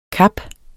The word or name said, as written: kap